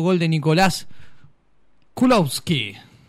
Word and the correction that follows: Kolowski: ¿Estás seguro que se pronuncia así? – Kolowski. – Kolowski. Kolowski. Bueno, está bien, usted lo dice.